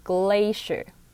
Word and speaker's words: glacier: 接下来是一个今天重要的单字，就是 glacier 冰河这个单字， C-I-E-R 这四个组合起来念作 sure，所以要念成 glacier。